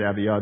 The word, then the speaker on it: Abijah: And it's Abijah is the way we say it, Abijah. They don't pronounce J's in Hebrew.